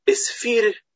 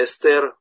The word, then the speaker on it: Эстер: Атуна, Афины, у нас неправильное произношение, правильно, все-таки по-русски неправильно. Эсфирь, а не Эстер, и Руфь, а не Рут.